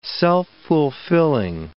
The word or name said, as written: self-fulfilling